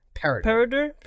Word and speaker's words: Peredur: Peridur, if I'm pronouncing that right. – Please spell. – P-E-R-E-D-U-R. Peridur.